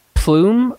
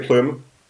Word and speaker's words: plume: And by the way, do they say plume or plum? – The French say plume.